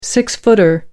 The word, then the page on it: six-footer: American English